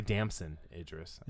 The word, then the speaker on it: Damson Idris: And then you had Damon Idris, not to be confused with Idris Elba. – Yeah, I've never seen him in anything. – Damson Idris. I said his name wrong.